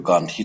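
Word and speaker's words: Gandhi: گاندی رو گاندهی تلفظ میکردن و بهاوانم به معنای ساختمان بود.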